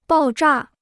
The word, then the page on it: 爆炸: bào zhà